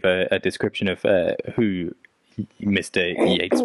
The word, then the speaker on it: Yeats: How do you say his name? Yeats or Yeats? – Yeats. – Yeats. – Oh yeah, and Yeats.